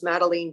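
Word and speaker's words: Madeleine: It rhymes with Dean. My first name rhymes with Dean. It's Madeline Dean.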